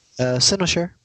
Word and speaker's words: Cynosure: Is it Cynosure? – Cynosure. – I was pretty close.